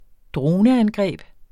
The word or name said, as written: droneangreb